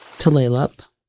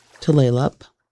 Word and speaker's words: Tulalip: Tulalip, Tulalip. The name is mispronounced by so many people, but Tulalip is nearest to the right pronunciation for the Snohomish Indian word Duflelap, which means the long bay.